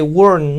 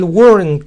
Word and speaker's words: weren't: You weren't sleeping. You weren't sleeping. Notaron que la T final de weren't no se oyó. El weren't, como ven, suena un poco borroso.